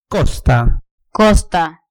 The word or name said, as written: costa